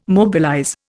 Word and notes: mobilize: Indian

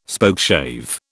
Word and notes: Spokeshave: British English

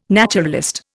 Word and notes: naturalist: Indian

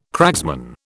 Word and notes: cragsman: British English

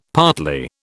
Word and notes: partly: British English

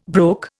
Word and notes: broke: Indian

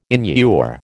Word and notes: inure: American English

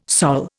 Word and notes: Sol: Indian

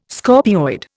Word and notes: scorpioid: Indian

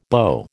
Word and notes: beau: American English

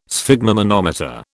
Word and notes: sphygmomanometer: British English

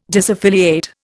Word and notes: disaffiliate: Indian

